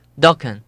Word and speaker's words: Dokken: Dokken.